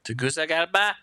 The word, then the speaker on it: Tegucigalpa: And so then we drive to Tegucigalpa. Did I say that right that time? – Tegucigalpa.